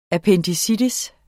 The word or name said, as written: appendicitis